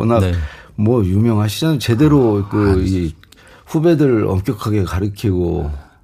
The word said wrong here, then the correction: 가르치고: 가르키고가 아닙니다. 가르치고.